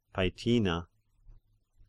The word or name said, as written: Paetina